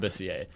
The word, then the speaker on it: Labissiere: Kept saying Labissiere, but I've heard it both ways. I feel like it should be Labissiere. That's just a cooler pronunciation. – Yeah, that's what I'm going with. – We're going with Labissiere.